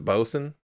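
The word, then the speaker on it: boatswain: You know how Bosun is spelled Botswain, but it's pronounced Bosun.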